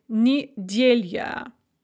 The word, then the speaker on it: неделя: If you have an unstressed ya at the end of a word, it can often be pronounced as a kind of a ya sound, as in неделя or кухня.